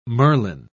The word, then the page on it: Merlin: British English